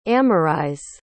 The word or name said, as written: amarise